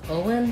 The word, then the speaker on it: Owen: The stress on Owen falls on the O syllable, and O is a diphthong in this name. Owen. Make sure you hold the O nice and long. And then the letters EN say the schwa sound, and the N, un.